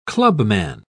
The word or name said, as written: clubman